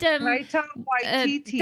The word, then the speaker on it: Taika Waititi: Tahiki Wahiki. – No, it's not Taika, Kelly. Come on, Kelly. You can do it. – Taikaiki. – Taiki. – Oh. David Cohen. – He did. – Taika Waititi.